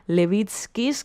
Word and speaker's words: Levitskis: Me acaba de escribir una oyente lituana que tenemos diciendo que se pronuncia así, Levitskis, que ella ha estado en sus conciertos en Lituania y que es maravilloso, que disfrute mucho el ganador del concierto.